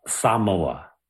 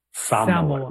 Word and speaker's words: Samoa: They pronounce it Samoa. Samoa. More of like an emphasis on the first syllable.